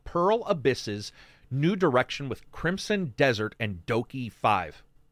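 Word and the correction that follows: DokeV: It's pronounced Dokibi.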